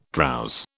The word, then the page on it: drowse: British English